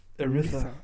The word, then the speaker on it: Aretha: Also äh, Arthur Franklin, äh, nee, jetzt habe ich schon wieder falsch ausgesprochen. – Aretha? Aretha.